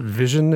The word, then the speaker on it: VisiOn: but they came up with this product called I think you pronounce it vision. It's visi on, like visit calc sort of a thing. But if you put the two words together, it's vision, which I think is sort of a play on words because what this is is a graphic user interface for the IBM PC.